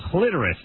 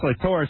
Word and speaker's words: clitoris: So she said, a doctor friend of mine told me it was clitoris, not clitoris.